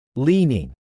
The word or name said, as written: leaning